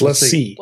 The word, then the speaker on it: LaCie: So I don't know how to pronounce it, but my next one is the LaCie. – LaCie. – Rugged, huh? – It's LaCie. – What was it? LaCie.